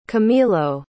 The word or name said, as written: camilo